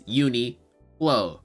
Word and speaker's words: Uniqlo: I don't know where he added the extra qua from, but it's Uniqlo, right? That's the only way I've ever heard people say it.